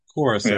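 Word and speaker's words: Curaçao: Curacao, right? – Curacao, yeah.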